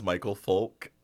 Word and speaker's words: Micheal Foulk: My name is Micheal Foulk.